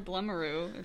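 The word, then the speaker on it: Blumaroo: So first of all, a couple days ago, it was Bloomeroo Day. – Or Blumeroo, if you're me. – Oh, I didn't know that was how you pronounced it.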